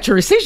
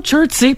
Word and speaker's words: Chelsea: À Chelsea, on prononce pas le thé. Hein? – Il n'y a pas de thé.